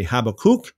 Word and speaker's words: Habakkuk: Now, I know I understand the correct way of pronouncing this in the Hebrew would be Habakkuk. I'll just say Habakkuk, as people more commonly know him as Habakkuk.